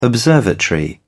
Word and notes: observatory: British English

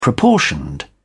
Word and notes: proportioned: British English

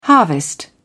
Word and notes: harvest: British English

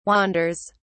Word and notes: wanders: British English

